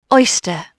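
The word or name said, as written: oyster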